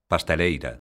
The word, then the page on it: pasteleiro: galega